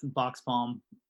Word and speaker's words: Boxpalm: So your voice is the one that actually gets to say it properly. – It's Boxpalm.